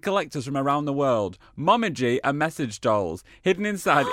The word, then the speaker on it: Mom-G: We're Mom-G, pronounced Mom-E-G.